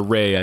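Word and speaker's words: Rea: Or Ray, I think is actually how you pronounce his name.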